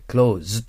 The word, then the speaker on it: clothes: clothes と書いて clothes という S の濁った発音になりますね。